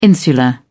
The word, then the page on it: insular: British English